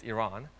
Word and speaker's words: Iran: I'm from Iran, or the correct pronunciation is Iran,